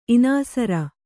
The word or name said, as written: ಇನಾಸರ